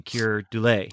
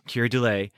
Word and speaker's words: Keir Dullea: It's very calculated, and and Kira Dula, we just looked up how to how to say his name, Keir Dullea. – Dullea. – Keir Dullea.